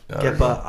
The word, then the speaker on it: Kepa: Kepa. – Kepa.